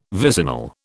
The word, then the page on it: vicinal: British English